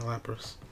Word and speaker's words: Lapras: Oh, you're gonna get me a Lapras.